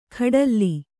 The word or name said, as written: ಖಡಲ್ಲಿ